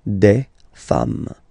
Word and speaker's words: des femmes: Femme, you write it like it was at the singular form and then you just add this S at the end and as we said, you don't pronounce it. So you get des femme, okay? Des femmes.